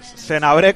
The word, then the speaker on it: Senabré: Senabré, con acento en la E, lo tiene muy claro.